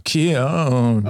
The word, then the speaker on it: Keyan: That's not how you pronounce my name, by the way. – Is it really not Keon? – No, it's not. Keon.